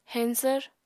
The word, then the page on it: heñsvr: Apatani